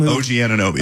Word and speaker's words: Anunoby: OG Anunoby.